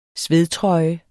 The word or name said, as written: svedtrøje